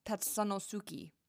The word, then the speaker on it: Tatsunosuki: It is called Head Spa Tatsun Head Spa Tatsunosuki. Tatsunosuki. There we go. That was my best Japanese accent.